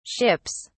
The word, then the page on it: ships: British English